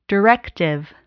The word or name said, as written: directive